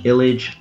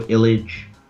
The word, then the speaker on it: Illidge: Illige. Last name is spelled I-L-L-I-D-G-E. On Twitter at Joe Illige.